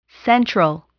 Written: central

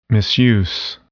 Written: misuse

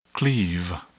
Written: cleave

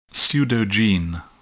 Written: pseudogene

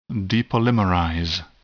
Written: depolymerize

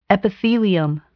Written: epithelium

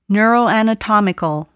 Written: neuroanatomical

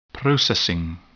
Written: processing

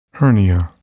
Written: hernia

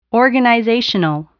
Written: organizational

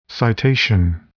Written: citation

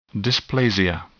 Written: dysplasia

